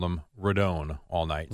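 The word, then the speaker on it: Rodón: No, I thought the Yankees and Rodone. Now we know that's how you pronounce Rodone. – Rodone. I never realized the tilde was over the O.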